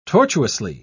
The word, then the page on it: tortuously: British English